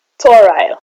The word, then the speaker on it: Toraille: Toral. – Could you spell that? – T-O-R-A-I-L-L-E.